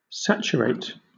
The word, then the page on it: saturate: British English